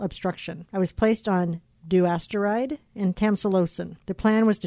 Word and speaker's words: Dutasteride: Dutasteride.